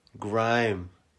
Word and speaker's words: Grym: And Grim is spelled G R Y M. Grime.